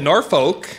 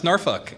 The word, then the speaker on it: Norfolk: I, I, I, uh, I, I, I, said Norfolk, and the people there kept correcting me. No, it's Norfolk.